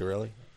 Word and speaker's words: Coscarelli: Well, I think the most important thing is that, you get the impression that both Bruce Campbell and the director, how do you pronounce his last name? – Coscarelli? Is that how you pronounce it? Coscarelli. Yeah. – Coscarelli.